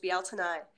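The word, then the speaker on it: Bealtaine: it's spelled like Beltane but pronounced Bealtanai, this holiday is coming up to celebrate the divine masculine and the divine feminine finally achieving union.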